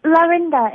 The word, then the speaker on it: Laurinda: Hi, my name's Lorinda. – Lorinda? – Would you spell? – Lorinda. It's spelled L-A-U-R-I-N-D-A.